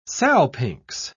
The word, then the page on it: salpinx: British English